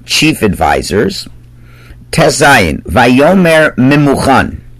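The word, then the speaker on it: Memuchan (ממוכן): It's written one way, but pronounced another way. I pronounced that Memuchan correctly, but it's written Mumkan, which means there's a blemish here.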